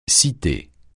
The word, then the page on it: cité: francais